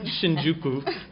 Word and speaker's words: Shinjuku: Shinjuku.